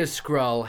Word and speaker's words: Skrull: You're saying I could be a Skrull having no knowledge of Skrulls.